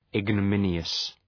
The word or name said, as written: ignominious